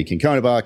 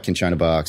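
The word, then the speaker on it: cinchona: So it can be Kinkonabark, Kinchonabark, Sinkchonabark. The CH and the C, depending on which country you're in and which vowel it follows, all sort of change.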